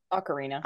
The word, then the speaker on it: Ocarina: The is it Ocarina? Is that how you pronounce Ocarina? that? – Ocarina. Ocarina. Ocarina. Okay, perfect.